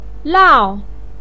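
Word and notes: lao4: pinyin; tone 4

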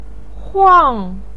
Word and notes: huang: 4

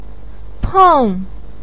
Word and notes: peng: 4

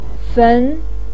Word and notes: fen: 2

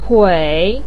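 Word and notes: tui: tone 3

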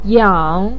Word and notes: yang: 3

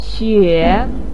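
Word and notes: xue: tone 3

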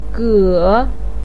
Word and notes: ge: 3

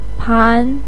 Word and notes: pan: 2

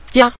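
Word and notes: jia: tone 5

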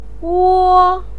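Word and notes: wo: tone 1

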